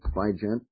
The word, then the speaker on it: Baigent: Now, I just imagine, since he quotes part of the Bible here, that the writer of this book, The Jesus Papers, Michael Bigier, if that's the correct pronunciation, B-A-I-G-E-N-T, so the French pronunciation would be B-J, or it would be an English pronunciation, Bygent, either one, Bygent.